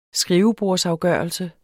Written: skrivebordsafgørelse

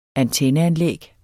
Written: antenneanlæg

